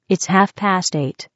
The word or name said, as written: half past eight